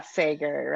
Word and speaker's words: Sager: And Ruth Ware, Riley Sager, I never know how to pronounce that. – Yeah, Sager, right.